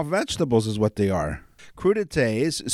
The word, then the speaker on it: crudités: Cut it into what? Crudités. Is that how it's pronounced? Crudités. Yeah. Oh boy. Crudités? Crudités. C R U D I T E S.